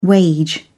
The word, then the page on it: wage: British English